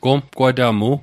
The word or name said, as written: gomqwatamu